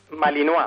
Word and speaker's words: Malinois: Estamos hablando de una raza, la del pastor belga Malinois, que es una raza Malinois. – Peligrosa. – Sí, vamos, se escribe Malinois. – Bueno, sí. – Pero se pronuncia en francés, uh-huh.